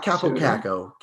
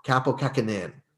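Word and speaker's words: Kaapo Kähkönen: Not Kapokako, Kapokakinen is his name.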